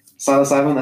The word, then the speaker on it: psilocybin: Psilocybin, that's how you say it? – Yeah, psilocybin. It starts with a P. So it's like pterodactyl, you know?